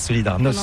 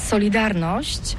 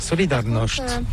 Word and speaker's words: Solidarność: Alors solidarité, en français on dit Solidarnosc, mais ici à Gdansk on ne prononce pas Solidarnosc. – On prononce Solidarność.